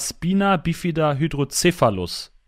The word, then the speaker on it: Spina Bifida Hydrocephalus: Und du hast seit Geburt einen Gendefekt, deswegen sitzt du in einem Schlitten, und zwar Spina Bifida Hydrocephalus. Ist das richtig ausgesprochen? – Ja, das ist korrekt ausgesprochen, ja.